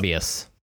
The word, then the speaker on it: Morbius: They are just, anything that Mobius draws is my God, like my God, that man is a fucking, there's nobody like Mobius. There isn't. Like he is Morbius, the living vampire. – Oh my bad. I've been pronouncing it.